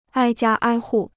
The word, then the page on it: 挨家挨户: āi jiā āi hù